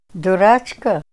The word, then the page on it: durachka: Ninilchik Russian